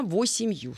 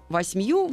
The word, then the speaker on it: восемью: Но восемью более книжная форма, восьмью более разговорная форма.